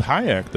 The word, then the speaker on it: Hayek: Selma Hayek, she got married. Her last name is something I can't pronounce. We'll call it Pinoy. – You did pretty good with Hayek, though.